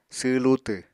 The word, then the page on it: sww lutw: apatani